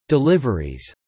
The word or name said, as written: deliveries